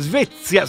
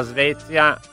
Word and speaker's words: Svezia: Si scrive con una Z, ma si dice con una o con due Z? Perché Svezia, se no sarebbe Svezia.